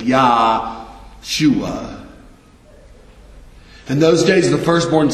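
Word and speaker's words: Yahshua (ישוע): In Hebrew, this is pronounced Yahshua, which we transliterate into English as Joshua.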